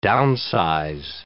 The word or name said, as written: downsize